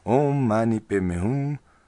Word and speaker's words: Om Mani Padme Hum: Lo suelen pronunciar Om Mani Padme Hum.